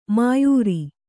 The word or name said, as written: ಮೋಯಿರ